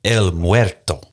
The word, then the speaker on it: El Muerto: It's officially known as El Muerto. I hope I got the Spanish pronunciation correct. – Pronunciation? Yeah, you did.